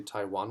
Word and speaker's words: Taijuan: Right now, and this is news this week, they got Tajon Walker, and I've been saying it wrong for 7 years. It's actually Taijuan Walker.